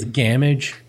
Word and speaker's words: Gamage: I think it's Gamage because it rhymes with damage.